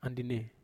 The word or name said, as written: andine